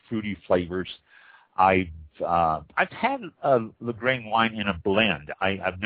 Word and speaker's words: Lagrein: Lagrein. This is spelled L-A-G-R-E-I-N, Lagrein.